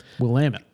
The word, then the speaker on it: Willamette: they get a little ornery if you say Willamette. – Willamette. – So just remind that it rhymes with... – God damn it. – Damn it, Janet.